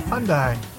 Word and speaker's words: Hyundai: Hyundai. – How do you say it? – It's just Hyundai.